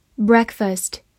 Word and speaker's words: breakfast: E A 字母组合发小口 e，所以 B R E A K 发 break。F A S T first, breakfast, breakfast。注意不要读成 breakfast，因为字母 a 后面没有 r 这个卷舌音。breakfast，它是一个名词，表示早餐。比如说吃早餐，have